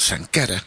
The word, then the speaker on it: Sankara: Sankara. Lo he dicho bien, ¿no? Sí, nadie me tira las orejas, perfecto.